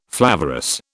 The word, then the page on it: flavorous: British English